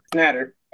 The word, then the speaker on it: Schnatter: So tonight we're going to be talking with Kenny Shatner. No, did I say that wrong? – Schnatter. – Schnatter, Schnatter, Schnatter.